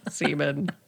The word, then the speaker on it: Simen: S-I-M-E-N, pronounced Seaman.